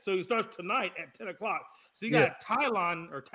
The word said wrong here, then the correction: Taillon: how do you say his name? – Jameson Taillon. – Okay, so you got Taillon tonight, then you got Cole, and then you got Cortez.